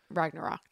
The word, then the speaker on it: Ragnarok: Ragnarok, that's how you say it.